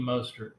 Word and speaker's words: Mostert: Mostert from Miami. – Raheem Mostert, yeah.